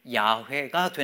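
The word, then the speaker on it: 야훼가 (יהוה): 나는 스스로 있는 자다라고 하셨는데 나는 스스로 있는 자다라는 말에 말에다가 모음을 붙여서 발음을 하면 그게 야훼가 되는 거예요. 그렇게 발음을 한 거예요.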